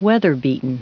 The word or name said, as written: weather-beaten